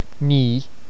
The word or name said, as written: ní